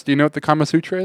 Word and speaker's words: Kama Sutra: No, what's the Karma Sutra? – I don't know. I was about to ask you what the heck that is. – I think I said it right. – Kama Sutra? – Kama Sutra? – I think so.